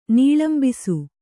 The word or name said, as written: ನೀಳಂಬಿಸು